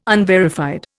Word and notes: unverified: Indian

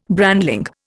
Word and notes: brandling: Indian

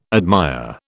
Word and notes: admire: British English

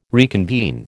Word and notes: reconvene: American English

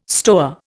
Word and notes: stoa: Indian

